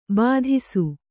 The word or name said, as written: ಭಾತಿಸು